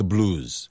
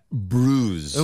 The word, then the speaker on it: bruise: Oh, by the way, Jake, this is not the blues. That's very different. Not the blues. This is B-R-U-I. So how'd you get that bruise? – 음악의 한 종류가 blues고요. 이거는 bruise니까 발음 주의하시고.